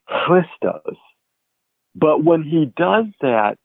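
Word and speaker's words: Christos (Χριστός): And Paul is the one who chooses the word the Greek word Christos, and here how I pronounce that. Again, it's like a clearing of your throat at the beginning of the word Christos. And as you say this word, again, there is the expulsion of breath.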